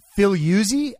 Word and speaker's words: Figliuzzi: Except, of course, for the guy he's talking to, Frank Filiuzzi, I think is how you say his name. Or at least that's how it should be pronounced in Italian. Or Frank Filiuzzi? Frank Filiuzzi, I think, would be how it is.